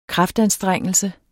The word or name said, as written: kraftanstrengelse